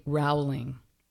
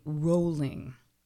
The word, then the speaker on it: Rowling: It's not J.K. Rowling. It's J.K. Rowling. It's really, really redundant. If you look at it, of course it's Rowling, but everybody pronounces it Rowling.